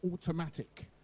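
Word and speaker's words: Autumnattic: track entitled Automatic, but it's spelt autumn as in the season in the year. So summer, spring, yeah, autumn, and then attic. But you say it automatic.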